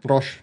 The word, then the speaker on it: Roche: Und dann habe ich auch noch Pharmaunternehmen hier, Roche, ne? – Roche, ja.